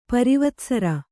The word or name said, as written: ಪರಿವತ್ಸರ